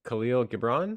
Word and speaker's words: Khalil Gibran: Um, we have The Prophet by Khalil Gibran.